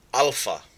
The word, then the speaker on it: alpha: So our pronunciation, alpha, ah, father, alpha, beta,